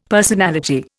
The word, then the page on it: personality: Indian